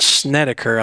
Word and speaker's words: Snedeker: Brent Snedeker, I think is how you say his name.